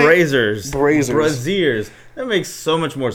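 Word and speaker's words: Brazzers: It's pronounced Braziers? – Yeah, Braziers, bro. What'd you think it was called? – I've been saying it like Brazers all this time.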